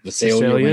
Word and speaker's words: Visalia: He started off as the Versailles Ransacker. – Visalia. – Is that how you pronounce it? Okay. – Yeah. – I thought it was like some weird French word. – No, it's California.